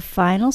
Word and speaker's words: final: In the word canal, spelled C-A-N-A-L, we hear a short A sound in the second syllable, nal. Canal. If you listen carefully to the word final, you'll hear that there is no vowel sound in the final syllable. Final. My tongue moves directly from the L sound to the L sound with no vowel in between.